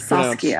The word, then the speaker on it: Saskia: Well, first of all, your name is Saskia. – Pronounced... So I saw it, and I've been to Saskatchewan many times. And of course, I wanted to say Sask. – Yep. A lot of Canadians do that, and a lot of Midwestern Americans.